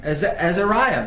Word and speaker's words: Azariah: Is that how you pronounce that? – Azariah. – Azariah, very good.